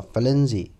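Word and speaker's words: Firenze: 可是它的意大利语发音呢，叫做 f l e n z i f l e n z i 就是我们伟大的浪漫的诗人徐志摩喜欢的，从意大利文直接翻译过来 f l e n z i 就是翡冷翠嘛。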